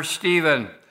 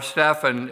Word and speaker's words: Stephen: We find the first martyr, Stephen, or Stephan, if you want to pronounce it that way, in the Greek, Stephanos, who was the deacon that was put to death and for giving a testimony about Jesus.